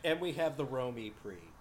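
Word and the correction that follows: Rome E-Prix: Rome? – It's going to be a busy weekend. – No, the Formula E Rome E Prix. – Oh. – It's very important how you pronounce it, apparently.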